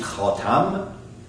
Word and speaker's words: khatam: And here, the word in Arabic language is very tricky, because you could pronounce it khatam, which will become the seal, or khatem, which means the one who puts a finishing end to it.